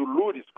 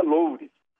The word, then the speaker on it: Loures: o Gedel, o, o Rocha Loures e o, eu descobri agora, tem gente pronunciando Lures como se fosse francês, parece francês, mas descobri uma cidade em Portugal que é Loures.